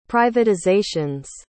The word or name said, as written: privatizations